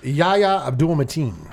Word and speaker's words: Yahya Abdul-Mateen: It's Yahya Abdul-Mateen.